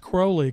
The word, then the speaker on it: Crowley: But his name was pronounced Crowley because it rhymes with holy, I guess.